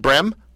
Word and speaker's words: Brehm: Is that how you say it, Brehm? – Correct.